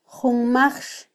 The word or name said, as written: junmajx